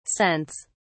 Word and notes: scents: British English

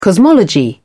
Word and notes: cosmology: British English

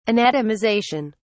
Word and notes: anatomization: British English

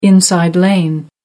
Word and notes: inside lane: American English

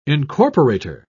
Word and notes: incorporator: British English

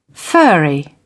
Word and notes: furry: British English

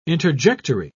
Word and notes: interjectory: British English

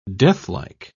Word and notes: deathlike: British English

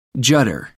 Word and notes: judder: American English

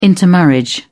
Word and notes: intermarriage: British English

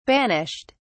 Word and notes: banished: British English

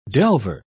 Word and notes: delver: British English

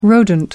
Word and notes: rodent: American English

